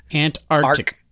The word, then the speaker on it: Antarctic: And it's not Antarctic. It's Antarctic. You got to have two K sounds in it. – Antarctic.